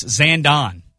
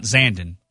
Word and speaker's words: Zandon: So apparently I've been saying it incorrectly. It's Zandon, not Zandon.